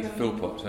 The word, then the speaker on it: Philpott: Yes, it's Toby Philpott, and Philpott is P-H-I-L-P-O-T-T.